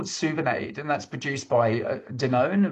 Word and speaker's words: Souvenaid: So Suvinaid.